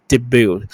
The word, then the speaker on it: debut: T 不发音哦，D E B U 哦，初登场那个 T 不发音，debut。 debut.